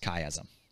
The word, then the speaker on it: chiasm: So for instance, I'm not going to talk a lot about it today, but you also have an idea of something called, this is kind of a fancy word, chiasm. Okay, that's spelled C-H-I-A-S-M, chiasm.